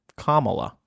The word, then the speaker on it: Kamala: That's easy to remember, because calm, and then, like, Pamela. Kamala.